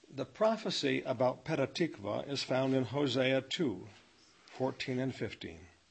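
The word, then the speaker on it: Petah Tikva (פתח תקווה): In Hebrew this is pronounced Petah Tikva.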